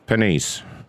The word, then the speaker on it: Penise: Panice. – Panice? – Yes. – Can you spell that for me? – P-E-N-I-S-E. Like this is real rich. – Like the French.